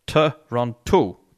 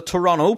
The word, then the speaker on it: Toronto: Now, whenever we're here in Northern Ireland, I would pronounce the Toronto Three syllables, right? Toronto police. Uh, but I've been told that's wrong. Even by guys in the band said, no, it's not Toronto, it's Toronto. You don't pronounce the last T.